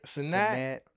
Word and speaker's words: Cenat: Cenat.